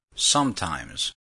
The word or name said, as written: sometimes